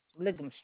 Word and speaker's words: Ligham: You're on, I'm going to pronounce this right, Lyham Street in Belleville. – Ligham Street. – Yeah, L-I-G-H-A-M, Ligham Street.